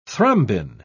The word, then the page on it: thrombin: British English